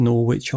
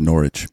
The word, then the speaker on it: Norwich: This brings up another obvious issue with the way that we handle competitive play in FIFA, though, is it's pronounced Norwich.